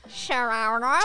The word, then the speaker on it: Saguaro: Saguaro.